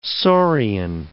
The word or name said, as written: saurian